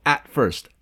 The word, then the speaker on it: at first: Miren que at first no se dice at first, se escribe at first, pero se pronuncia como la consonante frustrada, eso que ya hemos aprendido en nuestras clases de pronunciación. Hay consonantes explosivas como t, p, g, que se van a frustrar cuando se unen a otra consonante.